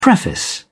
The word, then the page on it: preface: British English